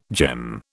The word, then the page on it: gem: British English